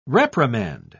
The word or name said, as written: reprimand